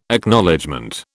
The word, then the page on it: Acknowledgement: British English